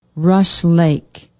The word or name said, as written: rush lake